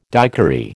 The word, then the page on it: daiquiri: American English